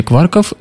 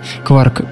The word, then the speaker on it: кварк: По идее, слово «кварк» должно произноситься так же, как слово «куорт», в которой буква «т» на конце заменена буквой «к», но обычно его произносят так, что оно рифмуется со словом «ларк».